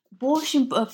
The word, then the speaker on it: borscht: Now, also another big part of your book deals with something called, and I hope I pronounce this correctly, borscht. – Yes. – Borscht.